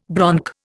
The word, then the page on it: bronc: Indian